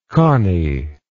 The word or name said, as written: connie